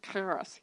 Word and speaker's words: charis: Just to give you some background, the Greek word for grace, which I can't pronounce correctly because it's one of those CH words, it's charis, but it's like charis, you know? And the Hebrew word for it is also a CH word, hen. So charis basically means beauty or attractiveness, and hen also means beauty.